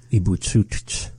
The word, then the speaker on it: Ibutsutr: How do you say that, though? – Ibutsutr. – Ibutsutr.